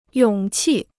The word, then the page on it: 勇气: yǒng qì